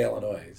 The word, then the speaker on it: Illinois: You don't pronounce the S. Mm-hmm. You say Illinois.